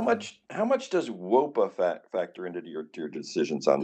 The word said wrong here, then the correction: wOBA: You're not even saying it right. And that's very disappointing. It's really disappointing for a man in academia to not even be able to say WOBA. But it's WOBA, BA, BA.